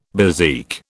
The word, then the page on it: bezique: British English